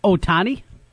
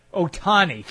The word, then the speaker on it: Ohtani: Ohtani. – Ohtani, thank you.